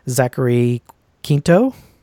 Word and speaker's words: Zachary Quinto: And it's like a short audiobook, but it's narrated by Zachary Quinto, right? That's his name. How you say his name? – Yeah. – Am I getting that right? – Yeah.